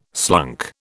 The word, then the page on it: slunk: British English